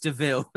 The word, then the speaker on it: de Vil: I love it's pronounced, it looks like Devo, but it's pronounced Deville.